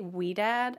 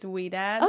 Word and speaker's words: Ouidad: I say WeDad. I'm not actually positive how to pronounce it. – It's okay. – It's OUIDAD. – Mm-hmm. – So I think it's WeDad.